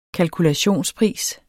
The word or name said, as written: kalkulationspris